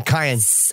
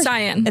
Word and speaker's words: cyan: C-Y-A-N, cayenne. – Cayenne. Mm-hmm. – Is it cayenne or cyan? – It's cyan. I was right.